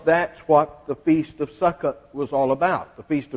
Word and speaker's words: Succoth: Succoth is, you don't pronounce the H.